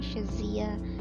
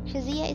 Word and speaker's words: Shazia: It's my full name, Shazia Jamil Pirizada. Shazia is spelled S-H-A-Z-I-A, Jamil's J-A-M-I-L, and Pirizada is P-I-R-Z-A-D-A.